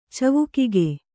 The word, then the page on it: ಚವುಕಿಗೆ: kannada